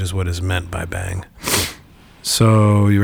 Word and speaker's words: Beyng: And I haven't said this in a while, so just a reminder, when we say bang, we are pronouncing B-E-Y-N-G, which is a respelling of being that Heidegger uses to describe a more eventful, I don't know, some different kind of being.